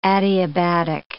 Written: adiabatic

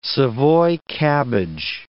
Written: savoy cabbage

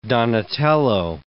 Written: Donatello

